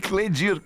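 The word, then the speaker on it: Kledir: O Cleito Cladir. – Cleito e Cledir, cara.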